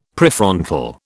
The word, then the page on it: Prefrontal: British English